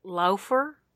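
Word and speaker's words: Läufer: How do you say an A with like an umlaut? Is that an umlaut? Two dots over it? – Laufer? – Laufer.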